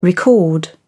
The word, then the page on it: record: British English